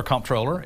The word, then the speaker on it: comptroller: I will say technically correct, either controller or comptroller.